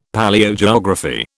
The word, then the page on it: Palaeogeography: British English